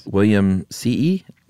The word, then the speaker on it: Williamsii: It is technically, the scientific name is Lophophora Williamsii. Now, we decided that two I's means you pronounce both, right? – You have to go E-E.